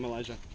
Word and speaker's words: Elijah: Okay, I'm Elijah.